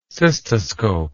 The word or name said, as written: cystoscope